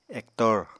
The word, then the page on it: ektor: Apatani